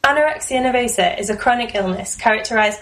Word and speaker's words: nervosa: Nervosa.